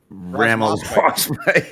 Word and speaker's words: Ramaswamy: Ramaswamy.